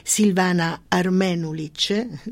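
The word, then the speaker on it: Silvana Armenulić: e cioè Silvana Armenulic, scusami sempre le mie pronunce. – No, giustissimo, sì. – Ah, questa volta in questo caso, sì, questo caso giusto.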